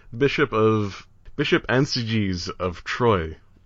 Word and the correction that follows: Troyes: Troyes. I really ought to be able to pronounce French better. Troyes. Troyes. Let's go with that.